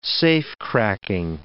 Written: safecracking

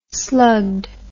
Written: slugged

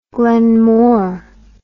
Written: glenmore

